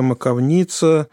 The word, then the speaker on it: маковница: Но если остановиться, «Лафертовская маковница», я такое ударение слышала. Да. – «Маковница». Это я не про... – Нет, Лена настаивала на этом ударении, автор.